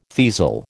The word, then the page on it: teasel: American English